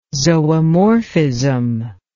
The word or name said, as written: zoomorphism